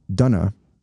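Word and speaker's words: Döner: They said that the Germans say it Dunna.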